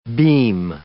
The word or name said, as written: beam